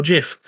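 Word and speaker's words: Giff: Half of them say it with a hard G and half of them say it with a soft G, so Gif or Jif.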